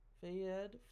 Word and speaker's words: Fayed: before the Princess of Wales was killed in 1997, car crash along with her boyfriend Dodi Fade, Fayed, Fayed.